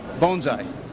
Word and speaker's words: bonsai: Bonsai. Bonsai. Not bonsai. Bonsai you yell when you're in a plane attacking Pearl Harbor. Bonsai is how to pronounce it.